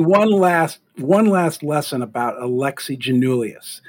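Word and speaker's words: Giannoulias: I want to stress this, that when you think, and this is your mnemonic for this, when you think of Alexi, think of him holding a martini with gin in it. So you think like Alexi, you know, he's got a little bit of a James Bond look to him. Janulius. That's how you say his name, okay?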